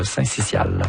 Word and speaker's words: syncytial: Non, selon le dictionnaire Usito, on prononce le syncytial. – Voilà. Syncytial, voilà.